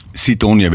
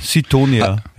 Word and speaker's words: Sidonia: Sidonia wird das ausgesprochen, okay. – Sidonia, ja.